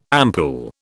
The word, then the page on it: ampoule: British English